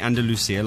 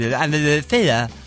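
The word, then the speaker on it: Andalucía: So instead of saying Andalusia, like we would... Andalusia. Like that. – That's not how they say it in Spain, Andalusia.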